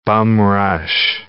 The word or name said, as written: bum-rush